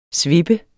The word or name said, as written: svippe